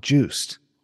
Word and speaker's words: Joost: Even though his name is Danish, and it's spelled J-O-O-S-T, but people would just call him Juiced.